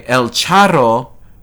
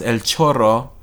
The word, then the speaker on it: El Charro: Written by El Charro, not to be pronounced El Chorro, because that's diarrhea.